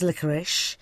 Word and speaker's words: licorice: Well, licorice is interesting, actually. It's quite right, it is licorice.